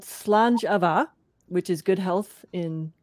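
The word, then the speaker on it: Slàinte mhath: But I'm renaming it Slangeava, which is how you say it in Scottish. It's that word that looks like Slainte Mahath.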